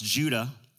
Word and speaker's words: Judah: He, in the Greek and Hebrew, it's just pronounced Judah.